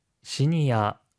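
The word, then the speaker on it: 시니아 (シニア): 시니아, 주니아와 같이 일본어의 외래어에서 어 발음은 보통 아로 발음되는 경우가 많습니다.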